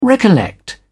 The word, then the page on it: recollect: British English